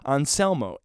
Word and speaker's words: Ancelmo: Anselmo James. Anselmo, A-N-C-E-L-M-O,